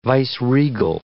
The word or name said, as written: viceregal